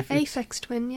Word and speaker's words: Aphex Twin: Apex Twin. – Oh, yeah, yeah. – Apex Twin. I don't know how you pronounce it. – Yeah, Aphex Twin, yeah.